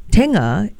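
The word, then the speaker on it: Tengah: But Tenga in Malay means middle or center. So it kind of fits, you know, it's a little bit of a... – I've been told it's pronounced Tenga.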